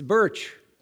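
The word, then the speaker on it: Berç: You know that you see that Birch's name is spelled B-E-R-C. Is actually not a pure C. It needs the tail. And the pronunciation of the modern location of Ephesus is Selchuk. It's Birch, and it's Selchuk.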